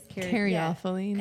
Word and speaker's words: caryophyllene: Like, yesterday we were doing labels for jars, and he was writing all those down, and I was like, carophyllene, and he's like, how it was pronounced. – Carophyllene. – There it is. – Caryophyllene. – Yeah. And Caryophyllene.